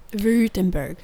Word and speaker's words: Württemberg: Württemberg.